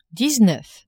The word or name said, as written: dix-neuf